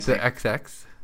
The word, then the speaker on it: The XX: How do you say that band? – The XX. – The XX. The double X.